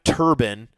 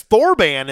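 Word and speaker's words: Thorbahn: Because Thorban is how I would think, but his name is Adam Turbin.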